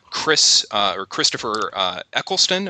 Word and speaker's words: Christopher Eccleston: And the reason I kept watching is because I knew Christopher Eccleston.